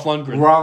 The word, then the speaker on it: Lundgren: Dolph Lundgren. – Yeah, it's very hard to say. – Kind of like Todd Rundgren, right? Like Dolph Lundgren.